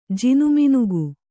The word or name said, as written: ಜಿನುಮಿನುಗು